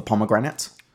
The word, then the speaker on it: pomegranate: The reason is that pommy is short for pomegranate. – Yeah. – Which, if you say with an Australian accent, rhymes with immigrant.